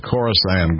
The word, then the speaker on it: Khorasan: I've pronounced that 14 different ways this week. K-H-O-R-A-S-A-N, Khorasan.